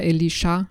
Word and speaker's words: Elisha: Aber auf Hebräisch ist Elisa ein Männername. Eigentlich wird er Elisha ausgesprochen.